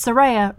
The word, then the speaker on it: Soraya: Like it's Soraya rhymes with Zendaya.